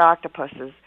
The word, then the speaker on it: octopuses: And we know, you know, if anybody has watched My Octopus Teacher, they know and they've learned about octopuses. And that is how you say it. It's the ES at the end.